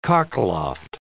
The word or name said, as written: cockloft